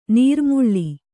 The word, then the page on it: ನಿರುಮ್ಮಳ: nirummaḷa